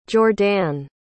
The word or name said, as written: jordanne